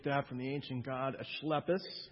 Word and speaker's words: Asclepius: It was an important religious center where the pagan cults of Athena, Asclepius, which is actually how you pronounce it, Asclepius, Dionysius, and Zeus were prominent.